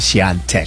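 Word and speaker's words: Świątek: Ba't ang basa, yung isa ko narinig, Swiatek? – No, Swiatek. – Swiatek. – Swiatek. – Pero yung pagkaka-spell niya, Swiatek. – Diba? Swiatek. Pero Swiatek. – Swiatek. – Swiatek ang pronunciation. Yan.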